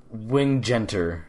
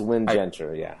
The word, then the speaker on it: Wingenter: Oh, wait, I think it might be Wingenter. – I think it's Wingenter.